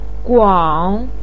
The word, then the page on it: guang: 3